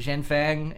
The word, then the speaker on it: Zhenfeng: The people for this, what is it, Zhenfeng in China. – Your pronunciation of Zhenfeng was almost dead on, and then you did like a weird racist China.